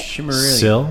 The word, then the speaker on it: Silmarillion: How do you say it? – Silmarillion. – Sil? – Marillion. – Malaria? – Marillion. – Malaria. – No. Sil? Marill? – Marill? – Lian. – Lian. Okay.